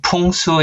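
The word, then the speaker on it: Pung Su (풍수): We will pronounce it Pung Su in Korean, but it follows the same Chinese characters as Feng Shui, as you would know in Hong Kong or other Chinese cities.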